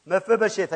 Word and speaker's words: Mephibosheth: Mephibosheth, I even got the pronunciation right.